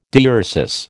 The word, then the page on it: diuresis: American English